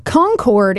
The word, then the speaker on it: Concord: Concord is affecting the politics of, I said that right, yeah, affecting the politics that happens everywhere else, right, mm-hmm.